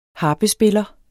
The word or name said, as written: harpespiller